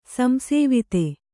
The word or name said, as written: ಸಂಸೇವಿತ